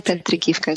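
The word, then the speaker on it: Petrykivka: Petrikivka is how you pronounce it.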